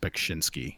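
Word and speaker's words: Beksiński: Oh yeah, like uh, I don't remember how to pronounce his first name, but I know it's the last name is spelled is pronounced Bekshinsky.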